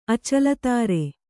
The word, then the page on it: ಅಚಲತಾರೆ: kannada